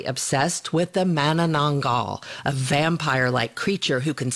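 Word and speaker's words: Manananggal: And I got to say that your pronunciation of Mananangal is quite impressive.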